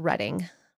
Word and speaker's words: Reading: It says Reading, but I think it's called, it's pronounced Reading. Reading, Pennsylvania's first ever pride rally, wielding a sign, a Bible, and his faith.